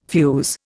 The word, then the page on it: fuze: Indian